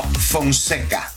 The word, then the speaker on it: Fonseca: All right, if you want to get connected, Julio Fonseca, am I saying it right? Francesca? – No, Fonseca. – Fonseca. God, yeah.